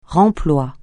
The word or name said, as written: remploi